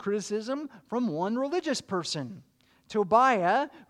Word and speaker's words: Tobiah: And you pronounce it Tobiah in Hebrew, unless you're fiddling on a roof, in which case it's pronounced Tevya, same name.